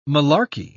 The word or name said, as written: malarkey